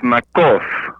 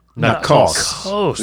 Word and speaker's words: Nacoste: First off, I'm a Louisiana Creole and that last name confuses people. That T is really not there. It's Nakos. Nakos. Yes, French. Nakos.